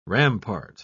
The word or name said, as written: rampart